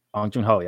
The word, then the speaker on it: Bong Joon-ho: Did I say it right? I don't remember. – Bong Joon-ho. – Yeah, that's right.